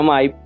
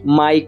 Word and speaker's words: MY: Se pronuncia MY, pero ese es, digamos así, el gran reto de nosotros. MY como mío en inglés,